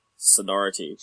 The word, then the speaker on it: Sonority: Genius Sorority, I think is how you say it. – Sonority. – Sonority. – Oh, wow, Sonority, yeah.